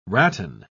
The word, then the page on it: ratan: British English